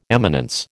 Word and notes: eminence: American English

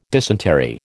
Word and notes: dysentery: American English

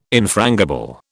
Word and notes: infrangible: British English

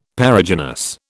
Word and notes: Perigynous: British English

